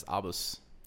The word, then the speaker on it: ABUS: So I Googled the pronunciation, and it gave me pronunciations for different countries, and they were all different. – Yeah. – It says the German one is Abus.